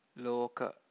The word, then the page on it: loka: Pali